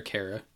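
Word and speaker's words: Khaira: And if so, would you think about adding Kyra, I don't know how to pronounce his name, from Edmonton? K-H-A-I-R-A. I know the player. – Jujar Kara. Yeah. – Kara.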